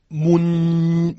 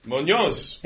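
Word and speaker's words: Muñoz: And the great thing is that very patiently he taught me how to pronounce his name. Diego Munoz. – Munoz. – Yep.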